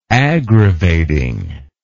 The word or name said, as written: aggravating